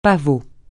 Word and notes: pavot: francais

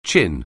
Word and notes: chin: anglais

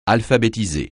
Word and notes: alphabétiser: francais

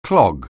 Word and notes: clog: anglais

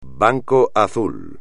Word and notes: banco azul: espagnol

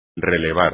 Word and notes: relevar: espagnol

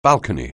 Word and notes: balcony: anglais

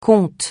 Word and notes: compte: francais

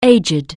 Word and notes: aged: anglais

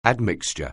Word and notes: admixture: anglais

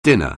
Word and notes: dinner: anglais

